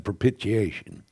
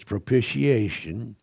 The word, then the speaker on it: propitiation: When I first started preaching, my sister says, you pronounced that wrong because I said propitiation. It's propitiation, and I learned that from her.